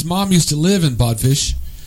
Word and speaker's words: Bodfish: Okay, so Bodfish right. – rhymes with codfish.